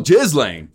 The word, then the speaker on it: Ghislaine: Maxwell Ghislaine. – You got to put the emphasis on the jizz.